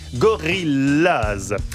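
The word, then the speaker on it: Gorillas: Gorillaz se prononce comme en anglais, Gorillaz.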